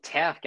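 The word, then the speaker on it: Taaffe: Is it Taffy? Is that how you say his name? – I think it's Taff. Taff.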